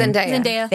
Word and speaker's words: Zendaya: Zendaya.